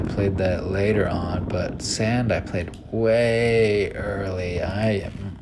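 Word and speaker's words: 3ND: And Sand, I'm gonna look this one up. Sand, it's spelled the number three, then ND. San is three in Japan, so sand.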